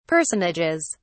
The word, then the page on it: personages: British English